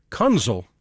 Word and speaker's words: Kunzel: Big ending from the Cincinnati Pops and Eric Kunzel. Rhymes with Funzel, and that is fun.